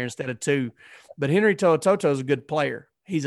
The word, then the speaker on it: To'o To'o: Now, now Henry To'o To'o or Henry Toe, I really don't know how to pronounce his name, and I should have. You did it right, right there. – Okay, I was, I was impressed with that.